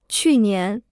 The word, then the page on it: 去年: qù nián